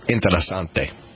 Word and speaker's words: interessante: Well, yeah, it's the pronunciation. It's Interessante.